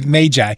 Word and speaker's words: magi: oh, okay, magi. Thank you. Uh, any of these uh, long A magi's were least likely men to uh, be given the secrets of God?